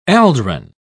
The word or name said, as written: aldrin